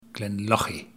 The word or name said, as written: Glenlochy